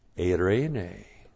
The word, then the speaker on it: Eirene: Subject number three is eirene. E-I-R, long E, N, long E. Eirene. And the Greek word eirene is the equivalent of the Hebrew word shalom. That's S-H-A-L-O-M.